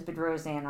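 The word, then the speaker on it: Bedrosian: Maiden name is Bedrosan.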